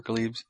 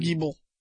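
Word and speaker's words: greeble: Glebes? Is that how you pronounce it? The little model detailing on the side? – Geeble. – Geeble? – I think it's Geeble.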